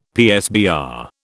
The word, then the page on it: PSBR: British English